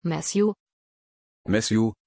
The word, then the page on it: Matthew: German